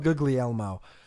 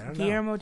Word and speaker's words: Guillermo: Good old Guglielmo. – Guillermo, I could say it this week. I don't know why. My mouth just didn't work right last week. Guillermo del Toro. – Done by Guillermo del Toro, with Ron Perlman as Hellboy.